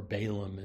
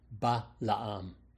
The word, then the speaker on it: Balaam: And of course, this is what we're going to be talking about today with the error of Balaam, or Balaam in the English, as it's usually pronounced. In the Hebrew, it's Balaam. He learned from Eve, who set Cain up with the illusion that he was Messiah.